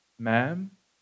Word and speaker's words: ma'am: And then also, ma'am, pronounced like jam.